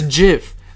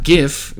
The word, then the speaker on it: GIF: It's GIF. Like the peanut butter. GIF. Right. Pronounced with a J, spelled with a G. – Exactly. Not GIF, because if you just add a T to it, then it's gift.